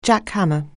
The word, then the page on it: jackhammer: anglais